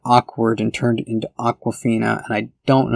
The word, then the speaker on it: Awkwafina: It's not spelled like the water brand Awkwafina, but it sounds exactly like the bottled water brand Awkwafina. But no, she called herself Awkwafina.